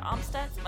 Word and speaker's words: Amstutz: And you can find me on all social platforms at Sandra Amstutz.